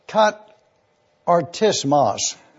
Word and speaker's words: katartismos: The word katartismos, the way you pronounce it is right here. This is a phonetic spelling. Katartismos. When you look at this, you'll see that the suffix of the word is the nominative masculine.